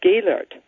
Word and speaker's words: Gehlert: My first name is pronounced Crystal, but it has nothing to do with the chandelier because the spelling is C-H-R-I-S-T-E-L, the German way. My last name is pronounced Gaylord. Most people say Gaylord, like the city in northern Michigan, but my last name is also German, so it's spelled G-E-H-L-E-R-T.